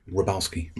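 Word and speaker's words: Wroblewski: Rabalski. – Oh, there we go. – It's with a W, though, which confuses me terribly right at the beginning.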